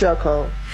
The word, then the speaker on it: Shacole: Our guy, Big Med, said that your name is Shay Cole. Is that correct? – It's Shacole.